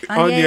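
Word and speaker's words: Agnès: Agnes is how it's pronounced.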